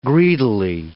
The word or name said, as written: greedily